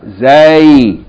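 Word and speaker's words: Zay (زاي): Zay, yani Za Fata Aleph Ya, Zay, or Za'un. Okay, so this letter Zay is pronounced like Z in Zu.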